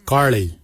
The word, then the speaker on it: gilyi: Darb is spelled D-H-A-A-B. It is a traditional knife, a darb. For boomerang, we say garli. Garli is the traditional name for boomerang. Garli is spelled G-I-L-Y-I, garli. For the traditional stone axe, we say gotch, gotch. Gotch is spelled G-O-O-D-J, gotch.